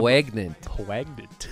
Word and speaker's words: poignant: Poignant. That's the French pronunciation.